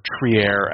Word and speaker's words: Trier: We had a lot of Alonzo Trier, or Trier, as our boy Macri likes to point out as the proper pronunciation.